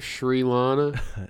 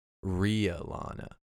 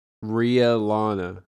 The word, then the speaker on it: Rhea Lana: Is that how you say it? Sri Lana? – No. Rhea Lana. R-H-E-A. Emphasis on the E. Some people might confuse it for Ray, but it's Rhea. – Rhea Lana.